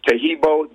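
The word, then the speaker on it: Taheebo: And the only tricky part is spelling Tahibo, which Tahibo is spelled T-A-H-E-E-B like boy O. So the first word is Tahibo.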